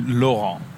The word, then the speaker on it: Laurent: So, that was wrong. It's actually Laurent. – Laurent. – Laurent. Yeah, it's spelled L-A-U-R-E-N-T.